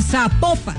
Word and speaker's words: Zapopan: Oiga, nos escriben los oyentes y que no se pronuncia Zapopan, sino que se pronuncia Zapopan.